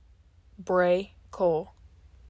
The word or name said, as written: Brae Cole